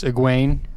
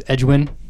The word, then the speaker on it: Egwene: The first time I read this book, I didn't have the Audible option, and I pronounced it's Egwene, right? – Yeah. – It was Edgwin.